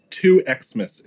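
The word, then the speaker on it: 2XMAS: two Xmas is our preferred pronunciation, just for the record.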